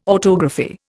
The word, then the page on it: autography: Indian